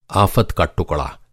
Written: आफ़त

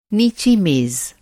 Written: नीचा

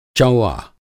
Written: चौआ

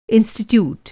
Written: इंस्टीट्यूट

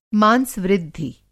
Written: मांसवृद्धि

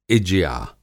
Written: इज्या

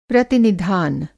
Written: प्रतिनिधान

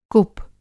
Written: कुप

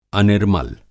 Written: अनिर्मल

